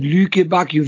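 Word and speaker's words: Lukebakio: Die Dazon-Moderatoren, die ganze Zeit Lüke Bakio, wie die den ausgesprochen haben. Ich fand das unmöglich. Entschuldigung, das war nochmal ein härter. Ich glaube, das stimmt sogar, weil der Belgier ist und aus dem französischen Teil Belgiens kommt.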